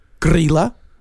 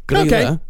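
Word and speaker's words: Grýla: These I know I'm actually pronouncing right. – Okay. – There is a Grilla and Lepaluthe. – Huh. – Yeah. Grilla and Lepaluthe.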